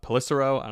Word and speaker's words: Pelissero: Pelissero. I'm not even sure how to pronounce that. Um, I think it's Pelissero.